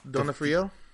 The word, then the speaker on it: D'Onofrio: D-O-N-O-F-R-I-O. – D'Onofrio? – D'Onofrio.